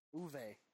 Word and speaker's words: Uwe: We don't want UA Bulls Green Lantern. – Uwe is his name.